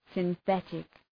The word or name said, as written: synthetic